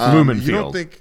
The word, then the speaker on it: Lumen: I think Lumen Field. Is it Lumen? – Sure. Yeah, I can see it written, but I can't pronounce it. – Yeah, it is. It's Lumen Field.